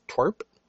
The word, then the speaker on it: TWRP: This is from the band, I believe it's pronounced Twerp. It's TWRP, and I believe it stands for Tupperware Remix Party.